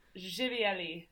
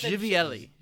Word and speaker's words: Živjeli: That was how do you say that again? – Živjeli. So with Živjeli. – With a J. Živjeli. Živjeli.